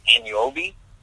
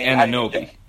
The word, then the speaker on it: Anunoby: I say Anunoby. – I say Anunoby.